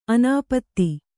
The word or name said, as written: ಅನಾಪ್ತ